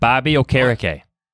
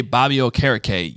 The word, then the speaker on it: Okereke: So, you got to think about the word carrot cake while you say it. I promise you, if you do that... – You're like a true radio man here. But if you're thinking carrot cake as you say Bobby Okereke, you will always pronounce it correctly.